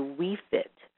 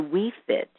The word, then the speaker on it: Wii: One of the things that we've learned is that there was a research study using something called the WeFit. I don't know if any of you are familiar with it, but the We is spelled W-I-I.